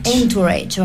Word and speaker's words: Entourage: Entourage, vos que sos traductora y sabes más inglés.